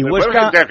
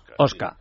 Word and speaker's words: Huesca: Cuando, y Huesca, y Huesca, que eso me duele mucho, le llaman Oscar. Oscar. Sí. Cuidado, porque Oscar, que como la H en español no se pronuncia.